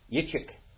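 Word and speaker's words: Yitzchak (יצחק): Yitzchak, Yitzchak, Yitzchak, Yitzchak, Yitzchak. What does it mean? – Joy, laughter. – Joy, laughter, pleasure. That's what it means. Joy, laughter, pleasure. That's what Yitzchak means.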